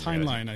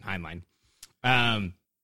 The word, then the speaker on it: Heinlein: I'm probably pronouncing his last name wrong. – It's Heinlein, I think. – Heinlein. The Dean of science fiction.